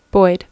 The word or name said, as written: boyd